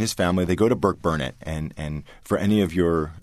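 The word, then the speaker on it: Burkburnett: Oh, well, very well, good pronunciation. – Yeah, good. – most people mess that up. Yes, yeah, it's Burke Burnett.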